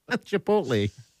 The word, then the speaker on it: Chipotle: So he's an insider. He pronounces it the right way. – Chipotle.